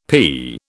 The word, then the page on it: pea: British English